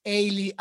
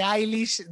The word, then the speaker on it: Eilish: ¿Qué sienten ustedes cada vez que alguien pronuncia mal el apellido de Billy y dice Ailey, Ailey, En vez de Eilish dicen cualquier otra cosa.